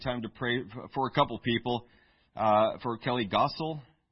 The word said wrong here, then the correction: Grossel: Is that pronounced? I'm sorry? Grossel. Thank you.